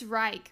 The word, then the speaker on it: Reich: It's Reich, right? Like when you say the Third Reich. – Spell it. – R-E-I-C-H. I think it's Reich and not Rake.